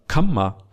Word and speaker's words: kamma: Kama, double M, kama, long A. Yeah, kama, kama. And if you're used to Pali, you can hear the difference quite easily. Can you hear the difference? Western ears, it's hard to hear the difference. But if you have a kind of Sinhala background, you will hear the difference straight away, because kamma, double M, kama is a long A. It's different, pronounced quite differently.